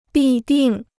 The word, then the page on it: 必定: bì dìng